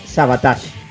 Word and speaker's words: Savatage: Todo eso lo vamos a discutir porque vamos a estar en este Rock entre Amigos especial hablando de Sabotage. ¿Lo dije bien, Javier? ¿Otra vez? – Perfectamente, amigo.